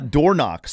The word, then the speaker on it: Dornoch: Door knock spelled D-O-R-N-O-C-H.